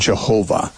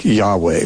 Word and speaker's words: Yahweh: and pronounce it Jehovah with a J sound at the beginning. More commonly, you know, Hebrew scholars would, I think, endorse a pronunciation more like Yahweh, which is, you hear that much more often now by those who are trying to be authentic.